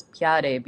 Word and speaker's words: piade: Like that example for cloud, it's fiade is the base, but if you put the earth marker in front of it, it actually comes out, the base comes out pronounced as piade, because the, you know, there used to be a nasal sound there.